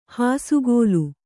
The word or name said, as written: ಹಸೆಗಲ್ಲು